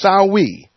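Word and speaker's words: Samhain: It's spelled S-A-M-Sam-H-A-I-N. Because it's Celtic, it's pronounced Sawee.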